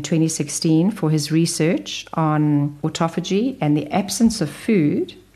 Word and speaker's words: autophagy: Another area is it increases something in the body called autophagy. Autophagy, I'm going to spell it, A-U-T-O-P-H-A-G-Y.